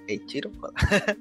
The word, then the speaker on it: Eiichiro Oda: Sí, porque el mangaka creador Eiichiro Oda, ¿sí? ¿Lo dije bien? Sí, Eiichiro Oda, se tuvo que someter lamentablemente a una cirugía ocular.